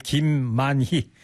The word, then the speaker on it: 김만희: 그리고 김말이가 아니고요. 김만이에요. 만희. 만만자 기쁠희자 발음. 사람 사장님 이름이에요. 김만희. 김말이 뭐 떡볶이 그런 게 아니고 김을 만다 그런 게 아니고.